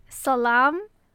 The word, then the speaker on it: Salam: Well, how are you supposed to pronounce it? – Okay. Salam. – Salam.